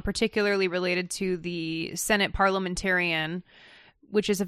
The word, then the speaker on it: parliamentarian: Everybody who called mispronounced it. Not just Jeff, everybody who calls mispronounces it. – For the first day, I kept calling it parliamentariat.